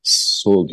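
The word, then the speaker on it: Sall: Ve seçimden sonra da şeffaf bir seçim süreci geçirdiği için seçimleri erteleyen Cumhurbaşkanı Sol diye okunuyor herhalde.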